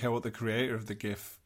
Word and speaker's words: GIF: Either way, it's graphical, which is a hard G. – It's a GIF.